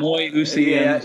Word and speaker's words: homoousian: It's as every seminarian student goes through that horrible history of Christianity where they're like homoousian, right. And like I don't homoousian. – Yeah, right. Thank you.